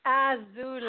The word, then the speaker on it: Azulai: Azulai. – How do you say your name? – A-Z-U-L-A-I.